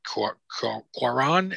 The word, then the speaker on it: Cuarón: And it was shot absolutely beautifully by Alfonso Cuarón. Am I saying his name right? – Yeah.